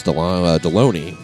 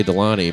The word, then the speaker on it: Deloney: Wednesday nights, myself and Asian Pat Dixon, along with occasional visits from meteorologist Alexis Deloney. Excuse me. She hates it when I say Delaney, but doesn't Alexis Delaney sound sexier than Alexis Deloney? – Yeah. – Which rhymes with fucking baloney.